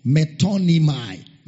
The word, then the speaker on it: metonymy: Metonymai. Metonymai. Metonymai. It's spelled as M-E-T-O-N-Y-M-Y.